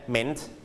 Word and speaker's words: meant: คำว่า mean ตัว a ไม่ออกเสียงนะครับเป็น silent letter นะครับจะออกแค่ meant นะ meant Are you done?